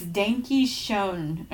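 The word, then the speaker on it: Danke Schoen: It's German, so and I'm like, what does this say? Oh, and good luck with this one, Aaron. It's Danky Schoen, or you know, whatever.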